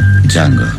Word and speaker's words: Django: Django. – Sai come si scrive? – D-J-A-N-G-O. La D è muta.